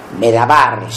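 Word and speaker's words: Medawar: se llama Peter Mirwar, Medabar, si uno quisiera imitar la pronunciación de un árabe.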